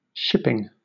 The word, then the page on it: shipping: British English